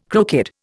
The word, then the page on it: crooked: Indian